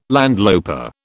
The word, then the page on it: landloper: British English